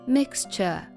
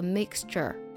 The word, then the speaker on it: mixture: 那我们只要记住一点，就是英式发音呢 mixture T-U-R-E 发的是 a 的音，没有儿化音。但是呢，在美式发音当中呢，见到字母就要发儿化音，所以它读作 mixture。